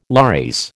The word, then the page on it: lares: American English